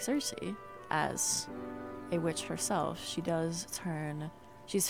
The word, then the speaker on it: Circe: If you've never read Circe, or if you've never heard of Circe, technically her name is pronounced something along the lines of Circe, if you're going off Greek mythology.